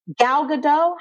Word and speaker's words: Gal Gadot: How do you say her name? – Gal Gadot.